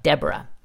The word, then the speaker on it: DBRA: DBRA, which is pronounced like Deborah.